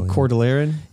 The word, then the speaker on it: Cordilleran: So in modern terminology as applied to the American Cordilleran, how do you say that word? – I say Cordilleran.